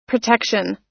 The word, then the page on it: protection: American English